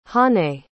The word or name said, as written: hanae